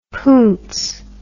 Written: poonce

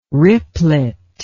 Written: ripplet

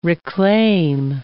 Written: reclaim

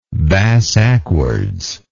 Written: bass ackwards

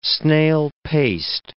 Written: snail-paced

